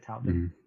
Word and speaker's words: Touton: Yeah, I like the first thing I want to show is the author of the article, Larry Alex Touton. Is that how you say it? Touton? – Mm-hmm. Yeah.